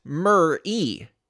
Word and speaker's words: Meri: Well, it's actually spelled M-E-R-I and better pronounced Mer-E.